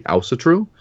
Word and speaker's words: Asatru: So there's lots of different pronunciations between Norway, Denmark, Sweden, and Iceland. In Iceland, they say Ausatru.